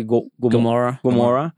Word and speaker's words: Gomorrah: Well, we've seen this in the Bible before, you know, Sodom, you know, and how do you say Gomorrah? – Yeah.